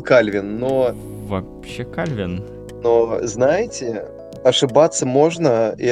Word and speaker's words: Кальвин: Почему Кальвин? Потому что ударение такое вроде как. А, ладно, окей. – А, потому что он... Нет, нет, нет, понятно, он, фран... он из Франции. Ударение на последний слог.